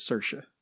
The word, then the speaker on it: Saoirse: Of Saoirse Ronan's character, Ellis Lacey, a young Irish immigrant. – Ailish. Yeah, and it's Saoirse. – Saoirse. – You're going to have problems with these.